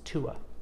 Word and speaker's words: Tuatha: The Milesians thunder across the sea and meet the Tuatha. Irish pronunciations are a little weird. It's Tuatha. but it's spelled T-U-A-T-H-A.